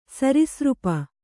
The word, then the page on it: ಸರೀಸೃಪ: Kannada; sarisřpa